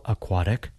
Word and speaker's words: aquatic: We spell aquatic like this. A-Q-U-A-T-I-C. Aquatic can be difficult to pronounce. It has three different vowel sounds, a, a, I.